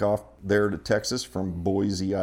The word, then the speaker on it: Boise: There's no Z in Boise.